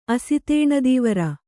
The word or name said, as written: ಅಸಿತೇಣದೀವರ